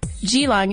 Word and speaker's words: Geelong: For instance, Geelong isn't with a hard G like in golf. It's a soft G like, gee, that's long.